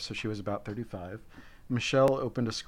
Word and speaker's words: Michel: It's pronounced Michel. – Michel. Michel, my belle.